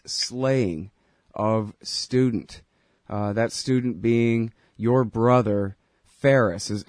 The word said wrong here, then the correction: Faraz: Am I saying his name right? – It's Faraz. – Faraz, okay.